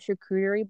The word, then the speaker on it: charcuterie: Yeah, they like brought out like the charcuterie board, or is that how you say it? – Yeah.